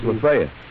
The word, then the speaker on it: Lafayette: Do you know whether or not that's right? – It's Lafayette.